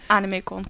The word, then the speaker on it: Animecon: Zeg ik dat goed? – Ja, Animecon, correct.